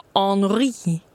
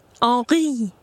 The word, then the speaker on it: Henri: Henri Kagan. – Ja, men jag tror ändå att det ska vara Henri. Henri. Liksom lite mer så, va? För att det ska vara riktigt franskt.